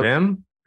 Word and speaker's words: Wim: Wait, so is that's it like the Vim? It's spelled with Vim a W. Benders. It's not Wim. – Yeah, W's in German and Dutch are V.